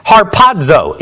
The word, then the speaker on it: harpazo: The phrase there, caught up, translated into English from the Greek word harpazo, is how you would pronounce it when you're trying to say it in English and don't really know Greek like I do.